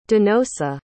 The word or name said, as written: donosa